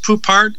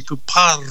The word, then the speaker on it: Poupart: Well, you can pronounce it Poupart, or if you want to be a real French, you can roll the R and say Poupart.